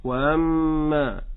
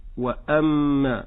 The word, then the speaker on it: وأما: أما بالنسبة للأخطاء فكما ذكرت في الآية الشبيهة لها هي الهمزة، لا أقول وأما، إنما أقول وأما. ما الفرق؟ في النطق الأول كانت الهمزة مسهلة، والصحيح أن تكون محققة.